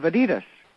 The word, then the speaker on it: Adidas: Big part of, in the last 60 years, of Adidas. – It is, and I'm actually very proud of you because when you introduced me, you said from Adidas Golf, and that's actually the correct way to say it.